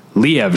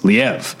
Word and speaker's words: Liev: I'm just saying, I recently learned that his name is not pronounced Liev Schreiber. It's Liev. The emphasis is on the second syllable.